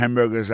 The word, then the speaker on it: hamburger: N'a pas remarqué que dans le mot hamburger, ça a comme tu as dit, il y a trois syllabes là-dedans. Quand tu appuies et puis ça, c'est sous premier syllabe là.